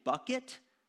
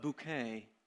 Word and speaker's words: Bucket: don't pronounce her last name Bucket, but Bouquet.